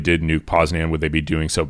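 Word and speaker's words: Poznań: Yeah, but the issue is, if Poznan, is that how you say it? – Poznan.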